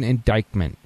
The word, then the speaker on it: indictment: If you search, somebody actually did the search, you search for Hillary Clinton indictment. Did I say that right? Indictment? – Oh, yes.